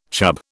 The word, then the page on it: chub: British English